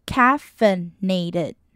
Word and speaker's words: caffeinated: I 就会不发音 caffeinated 再来看到 alert 呢 A 在这里是发 a 的音 alert 再来呢我们看到 take a second 呢我们在句子里为了让你的顺一般也会做连音 take 跟 a 会连起来Take a second.